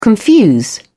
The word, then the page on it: confuse: British English